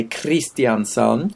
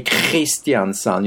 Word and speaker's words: Kristiansand: So, like some Norwegians will say Kristiansand, mm-hmm. and others will say Kristiansand. You know, with a rolling in the back of the throat,